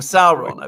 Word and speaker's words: Sauron: I wonder who Sauron is, or Sauron, I believe the correct pronunciation.